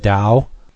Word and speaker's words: Tao: I do think that it's the heavy's name is pronounced Dow. So that would be even more up Jason's wheelhouse. T-A-O is pronounced Dow. Yeah. – Dow and not T.